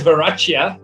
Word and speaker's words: Verachia: I'm glad you pronounced my surname in a very foreign, very interesting, very unique way. – What should I have said? – It's known as Verachia, but it doesn't sound as cool and as funky as the way you've put it across.